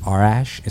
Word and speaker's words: Arash: And by the way, my name is Arash. It's spelled Arash, A-R-A-S-H.